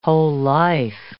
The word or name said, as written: whole-life